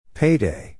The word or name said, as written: payday